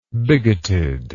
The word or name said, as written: bigotedly